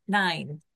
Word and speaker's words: Nain: It's spelled Nain, but that Greek word I looked up and listened to the pronunciation on Google, and it said it's pronounced Nine.